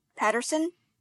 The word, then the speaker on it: Patterson: Patterson, PATTERSON.